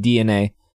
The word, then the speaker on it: DeNA: This is the D-E-N-A, but it's pronounced DNA.